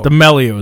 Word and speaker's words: D'Amelio: D'Amelio is how you say.